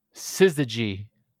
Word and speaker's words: Syzygy: But this is the Syzygy. Is that how you say it? Syzygy. – I don't even know if you're speaking English there. – That's right. It's S-Y-Z-Y-G-Y.